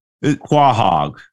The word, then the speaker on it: Quahog: Quahog or Quahog.